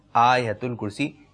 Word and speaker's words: آیت الکرسی: اچھا اس کا ایک تلفظ یہ ہے مدنی چینل کے ناظرین, آیت الکرسی آیت الکرسی کرتے ہیں لوگ, آیت الکرسی آیت الکرسی آیت الکرسی آیت الکرسی, یہ اس کا درست تلفظ ہے.